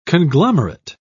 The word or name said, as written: conglomerate